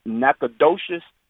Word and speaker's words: Nacogdoches: It's pronounced, I'm in both of those towns at least once a week. So it's Nacogdoches, Texas, which run off of Highway 59, and it's Natchitoches, Louisiana, which run off of 49.